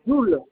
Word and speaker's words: Joule: Joule, J-O-U-L-E. L'enfant. – Joule. – Non, Joule, J-O-U-L-E. – Voule, d'accord. – J comme Julien, O comme Olivier. – Joule, d'accord. Et c'est quoi après Joule, rue Joule? – L'enfant. – L'enfant, d'accord. Donc L-O-N-G-F-O-R-T.